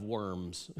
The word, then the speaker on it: Worms: how in English it's spelled out, the diet of worms, okay?